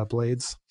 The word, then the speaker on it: Blades: Uh, Jazz and Lincoln Center Orchestra, A Night with Ruben Bla- uh, Blades, use the American pronunciation.